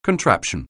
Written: contraption

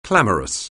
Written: clamorous

